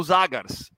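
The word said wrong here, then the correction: Žagars: né? Jagars na né, pronúncia mais correta.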